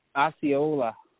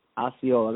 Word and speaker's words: Osceola: How do you say the town name? – Osceola. – Osceola. That's what I thought it was, but I didn't want to get that wrong because Wisconsin people get frustrated at that, don't they? – Yeah, a little bit.